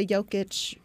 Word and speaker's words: Jokic: Nikola Jokic really loves his Sprite.